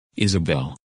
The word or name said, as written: Isabelle